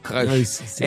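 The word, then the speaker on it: Krösche: È detto in tedesco Krösch.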